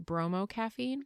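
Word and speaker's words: Bromo-Caffeine: Is it bromocaffeine? – Yeah. – It's like a bromance. That's how I remember it.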